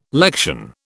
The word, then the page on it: lection: British English